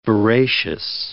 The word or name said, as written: veracious